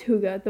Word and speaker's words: Hygge: So, pronounced Hygge, the word is said to have no direct translation to English, although cozy comes close.